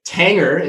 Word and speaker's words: Tanger: Tanger, if I'm saying that right, Ann, correct me if I'm wrong. That's right. Rhymes with Hanger from past episodes. – Tanger and Hanger.